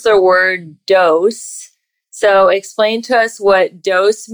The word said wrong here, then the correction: dowsing: We pronounce it dowsing.